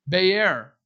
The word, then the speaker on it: be'er: But in the Bible, we find this word be'er. Be'er is how you say that. Almost like it looks like the word B-E-E-R in your Bible, like Beersheba.